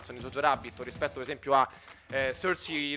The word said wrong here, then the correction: Saoirse: Ronan, adesso non so come si pronunci, in Piccole Donne sicuramente... – Saoirse eh, Ronan. Eh, Saoirse, Saoirse Ronan.